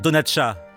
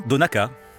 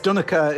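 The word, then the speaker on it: Donnacha: Un Français aurait envie de dire Donatcha, mais on dit bien Donaka. – Yes, Donaka is what is how you would pronounce it. – Oui, c'est bien Donaka dans la langue irlandaise. – So the CH is a K. – Le CH se prononce K.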